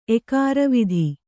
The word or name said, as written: ಎಕಾರವಿಧಿ